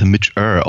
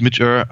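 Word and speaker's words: Midge Ure: Ähm, also ich fand, ich habe mich, hab mich sehr gefreut, aber ich glaube, ich, ich, bin mir ich bin relativ sicher, dass Mitch Err ausgesprochen wird.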